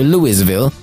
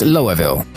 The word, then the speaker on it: Louisville: Co ciekawe, pisownia sugeruje, że jego nazwę czyta się Louisville, jednak byłoby to zbyt proste. Problemy z prawidłową wymową mają nawet Amerykanie. Okazuje się, że poprawną wymową, przyjętą przez mieszkańców tego miasta, jest Louisville.